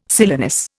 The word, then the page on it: silenus: Indian